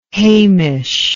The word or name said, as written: haimish